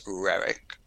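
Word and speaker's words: Roerich: Do you pronounce it Rorich? – So in my Russian pronunciation would be very bad. It was pronounced in the United States Rerich, and it was quite similar to that in Russian.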